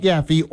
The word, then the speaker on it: Yaffee: Y-A-F-F-E-E, Yaffe. You can like the Yaffe Facebook page, follow me on X at Yaffe or Instagram at Yaffe Live.